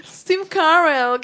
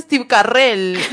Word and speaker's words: Steve Carell: No se dice así, se dice Steve Carell. Steve Carell se dice. Ay, ¿cómo que no? Ahí saltó la otra forra también a decir, ay, no, se dice Steve Carell, Este aquí, días.